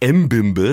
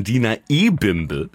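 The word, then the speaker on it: Dina Ebimbe: Er heißt nicht M-Bimbe, sondern Diener E-Bimbe.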